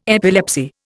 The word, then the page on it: epilepsy: Indian